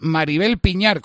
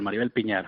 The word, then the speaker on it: Maribel Piñar: Y vuelven a confiar, o al menos eso me ha dicho antes, de cara a la próxima campaña, en Maribel Piñar, con acento en la A, no lo he dicho mal. – Sí, Piñar, Maribel Piñar, sí.